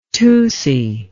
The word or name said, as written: tusi